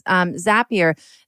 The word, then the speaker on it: Zapier: So it's like, is it Zapier or Zapier? Zapier? Phil's nodding. Okay. It's Zapier.